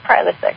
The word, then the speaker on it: Prilosec: How do you say it? – You're right, Prilosec.